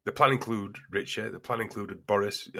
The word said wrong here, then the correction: Rishi: He's not called Richie. – What's he called? – Rishi. – I got his name wrong.